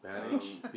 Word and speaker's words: Pjanic: Pjanic.